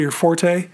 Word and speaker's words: forte: your forte or your fort. The word can be pronounced either way, believe it or not.